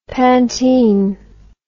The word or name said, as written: pantene